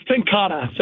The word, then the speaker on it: Tenkate: Hellas by Tenkate. Tenkate, is that? Okay, I want to say it right. – It's Tenkata. So it's spelled like Tenkate. – Yeah. – But it's, yeah, Tenkata.